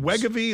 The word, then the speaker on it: Wegovy: Is it Wegovy? Is that how you pronounce Wegovy? – It? – Yeah.